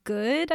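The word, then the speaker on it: Goode: Oh, his name is Matthew Good, I think is how you pronounce it. It's G-O-O-D-E.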